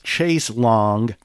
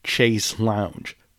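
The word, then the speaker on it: chaise longue: My brain also wants to say and read Chase Lounge, but it's spelled L-O-N-G-U-E. They just flipped a couple letters and changed Chase Lounge into Chase Long, and there's not a goddamn thing I can do about it.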